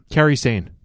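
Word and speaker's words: Kairi Sane: And how do I say her first name? Sane. – Carrie Sane. – Carrie Sane, thank you.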